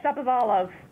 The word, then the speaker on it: Shapovalov: And, of course, with the accomplishments of our junior Wimbledon champion as well, Denis, and you're going to have to help me with the pronunciation, Shapovalov? – Yes, Shapovalov.